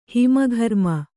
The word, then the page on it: ಹಿಮಘರ್ಮ: kannada